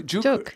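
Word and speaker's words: Juk (죽): So I was introduced to Juk. Is it pronounced Juk? – Juk, yeah. – J-U-K.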